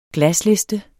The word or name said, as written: glasliste